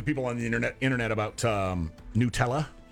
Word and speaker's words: Nutella: But the people who created the company say it's pronounced Nutella. They're the ones that get to tell us how to pronounce it.